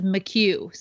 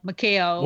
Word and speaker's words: McHugh: and it is he confirmed this week it is McHugh. So Phil was right. I've been calling him like McHale.